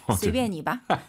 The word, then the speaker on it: 随便: 随便你吧. – 마음대로 하라고 하시네요. 네. 마음대로 해, 중국어로. – 随便你. – S-U-I-B-I-A-N이에요. 随가 아니라 随便.